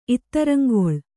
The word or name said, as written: ಇತ್ತರಂಗೊಳ್